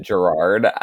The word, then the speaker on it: Gerard: Gerard, is that how you pronounce it? – Yeah, I saw that in my research. It's Gerard. – So, I'm just gonna pronounce it Gerard, because I'm like okay, sure, and he's not listening to this.